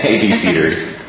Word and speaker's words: abecedary: So, an abecedary, am I saying that right? We're going to pretend that. – ABC-dary. Yeah, not a coincidence that the first four syllables are A, B, C, D.